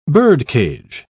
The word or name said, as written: birdcage